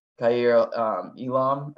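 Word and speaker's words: Kaiir Elam: And then, you know, two picks later, the, you know, the Bills move up and take Kair Elam. I believe that's how you say his name. – Yeah. Yeah.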